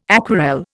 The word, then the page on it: aquarelle: Indian